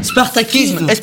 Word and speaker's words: Spartakisme: Alors, nous avons demandé au professeur de nous Spartakisme.